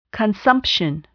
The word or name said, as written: consumption